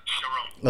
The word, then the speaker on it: Charon: Is it Charon or Charon? – Sharon. – Oh, Sharon. Okay.